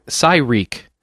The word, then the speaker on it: Syrek: It's Syrek. So like Psy as in psychic and Reek as in wreak havoc.